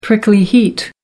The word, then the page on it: prickly heat: American English